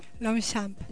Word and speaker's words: Longchamp: Porque no se pronuncia como se escribe. Ajá. ¿Se pronuncia? – Longchamp.